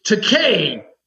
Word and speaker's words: Takei: Takei, as in to pay.